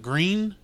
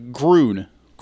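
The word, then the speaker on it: Grune: was it Green? Is that the... – Green? – Gren? Gwen? I don't remember the guy's name. – Oh, you're talking about Groon. – Groon, yeah, sorry. It's spelled like green.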